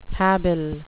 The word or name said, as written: habile